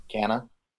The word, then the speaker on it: Cana: C-A-N-A. – C-A-N-A? – I think that's what it is. – How would you pronounce it? – Canna. – Canna, yeah. Okay.